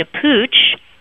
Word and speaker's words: Puig: And then you moved on to Pooch, which is confusing because it's spelled P-U-I-G, where you were the Executive Vice President of Sales and Marketing for the U.S.